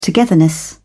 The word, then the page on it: togetherness: British English